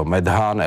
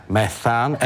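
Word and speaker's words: metan: no tak je to Medhán, Edhán, Probhán, Budhán, že? A on říká, co to říkáte? Já říkám, no uhlovodany. A on říká, a co to máte za výslovnost? Já říkám, no tak promiňte, Methán, Ethán, Profán, Busán.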